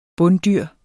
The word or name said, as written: bunddyr